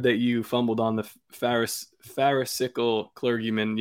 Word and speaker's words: Pharisaical: Pharisaical. I bet you that's how it's pronounced, but go ahead. – Yeah, and that is Pharisaical, yes.